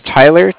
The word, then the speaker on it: Tyler: My name is Tyler, T-Y-L-E-R. And I'm calling with Cardiff. It's spelled C-A-R-D-I-F-F, as in Frank, Frank.